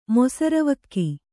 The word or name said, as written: ಮೊಸರವಲಕ್ಕಿ